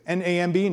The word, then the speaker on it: NAMB: NAMB, NAM is how it's pronounced, kind of like lamb, but NAM.